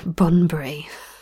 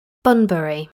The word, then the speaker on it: Bunbury: Whilst on a voyage from Jakarta in Indonesia to Bunbury in Bunbury. Is that how you say it? Bunbury, apparently, that's a better way of saying it.